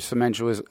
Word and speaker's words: cementitious: Just get regular cementitious. What's the word again? We say it wrong. – Cementitious grout, yeah.